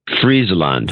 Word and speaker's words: Friesland: Friesland.